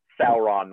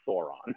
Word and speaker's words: Sauron: And I know it's Sauron, not Sauron.